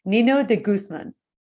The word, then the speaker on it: Nino de Guzman: So I'd like to give a very warm welcome to Andrea Nino de Guzman. – Is that right? Yes, you got it.